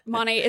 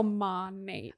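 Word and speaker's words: Monat: People say it different all the time. But Monate, it's Modern Nature is like the actual name. So Monate.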